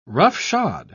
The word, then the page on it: roughshod: British English